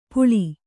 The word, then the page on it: ಪುಳಿ: puḷi